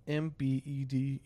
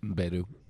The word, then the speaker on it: Mbedu: Yeah, who is Thuso Mbudu? I probably said that wrong. But is it Mdubu? – Mbedu. – Yeah, Mbedu.